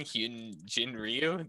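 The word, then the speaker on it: Hyun-jin Ryu: I like Hyunrin Jiu, or if I said his name wrong, Hyunjin Ryu. There we go. That's it. Hyunrin, imagine that.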